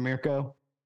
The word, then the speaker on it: Mirko: My one beef about it is, like, that was, at any other point in the game, that was an objectively correct decision from Mirko is the correct pronunciation.